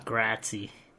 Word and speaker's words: grazie: Grazie, that's Italian. – You sure? – Yeah. – Grazie. – That's how you say it.